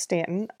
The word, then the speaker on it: Staunton: It's pronounced Stanton, I think. That's how most people pronounce it, but it is spelled Staunton, S-T-A-U-N-T-O-N. Go figure.